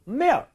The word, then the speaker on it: milk: 그러니까 L 발음을 정확하게 하면 milk가 돼야 되는데 그게 아니어도 적어도 L 발음 하려고 노력은 한다면 예를 들어 milk 이 정도만 해준다고 해도 충분히 알아듣습니다. 강세를 잘못 줘서 그럴 거예요. milk 이러면 안 돼요.